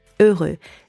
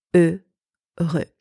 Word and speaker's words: heureux: Heureux. First, very important, the H is silent in French, so don't pronounce it.